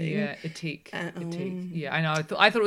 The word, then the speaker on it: Ethique: She was the founder of Ethique. – Oh, is that how you say it? – That's how you say it.